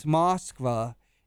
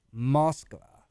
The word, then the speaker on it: Moskva: I, I just found out to pronounce Moskva, there's actually a little catch in it. Moskva. – There's Mo- like, ugh. And in fact, the people, the Russians say Moscow because it's Moskva, because that's how it's spelled, oh.